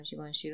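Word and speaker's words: Givenchy: درست گفتم جیوانشی رو سر؟ همیشه این همیشه اسم این برند من یه جور عجیب غریبی تلفظ میکنم. این دفعه درست بود.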